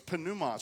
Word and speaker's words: pneuma: We would say pneuma. I think they actually pronounce the P in Greek, so it's pneuma.